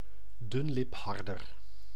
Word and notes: mulet: France; [my.lɛ]

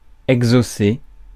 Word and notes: exaucer: France; [ɛg.zo.se]

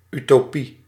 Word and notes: utopie: /ˌytoˈpi/

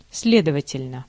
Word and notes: сле́довательно: /ˈslʲedəvətʲɪlʲnə/

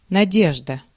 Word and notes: hope: American English; [hɔʊp]; /həʊp/; /əʊp/; GenAm; /hoʊp/

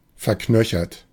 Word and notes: verknöchert: /fɛɐ̯ˈknœçɐt/

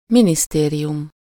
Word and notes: ministère: [mi.nis.tɛʁ]; France

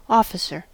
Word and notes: officer: American English; /ˈɑfəsəɹ/

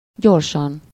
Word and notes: gyorsan: /ˈɟorʃɒn/

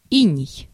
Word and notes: иней: /ˈinʲɪj/; inei

